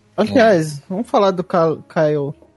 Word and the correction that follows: Kylo Ren: Kylo Ren.